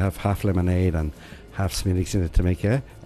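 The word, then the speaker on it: Smithwick's: And it was called, it's a funny spelling, the way you spell it. But pronunciation of it is, it's called Smiddix.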